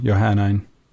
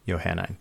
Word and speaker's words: Johannine: I'm going to add a third pronunciation just to keep it interesting. Johannine. – Johannine. There you go.